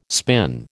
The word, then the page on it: spin: American English